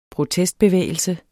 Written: protestbevægelse

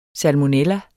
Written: salmonella

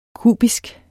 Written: kubisk

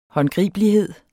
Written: håndgribelighed

